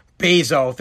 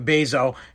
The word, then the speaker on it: Bezos: If Jeff, I'll say his name again, Bezos, thank you, Bezos.